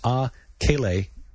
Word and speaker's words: A Kele: It's A Kele, A and then space, K-E-L-E, which is based on the Hawaiian for A, meaning hot and fiery, and then Kele, which means impurity, signifying radiation.